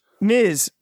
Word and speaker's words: Ms.: Ms. Ms. Pronounced M-I-Z.